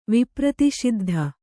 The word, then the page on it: ವಿಪ್ರತಿಷಿದ್ಧ: vipraṣiddha